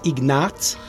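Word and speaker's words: Ignác: Normalement, il était baptisé euh, sous le prénom Ignace écrit à la fin avec un CZ parce qu'on prononce Ignatz. C'est comme le tsar ou le tsunami, c'est ce son-là qu'il faut entendre.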